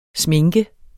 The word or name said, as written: sminke